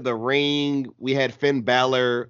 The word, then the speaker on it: Balor: I just try to pronounce names how the people who have the name say it, and I think Finn just says Balor.